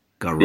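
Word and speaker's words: gharar: I'm going to mispronounce them, right? Riba, Maisir, and Garar. Yeah. Did I pronounce that properly? – Yeah.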